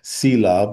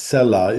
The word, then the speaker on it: Selah: Selah, which I've always mispronounced as Selah, but it's Selah is more accurate.